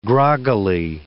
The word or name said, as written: groggily